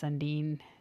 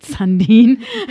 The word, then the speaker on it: Sandin: And then once Riley got injured, then obviously we brought up Sandin, who's been a really good, Rasmus Sandin, not Sundin.